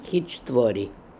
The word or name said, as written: Heechtvori